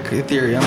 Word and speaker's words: Ethereum: Like Ethereum.